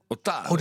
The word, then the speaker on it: Otaru: Leggen we trouwens de klemtoon goed? – Otaru. – Dus de klemtoon op de A.